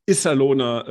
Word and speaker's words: Iserlohner: Irgendein Cody Lempel macht irgendwelche Clowns-Emojis und äh, habe irgendwie gesehen, geht um Schlägereien, geht um den ähm, Iserlohner, äh, habe ich das richtig ausgesprochen? – Hast du richtig äh, ausgesprochen, ja.